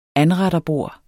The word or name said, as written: ANRETTERBORDS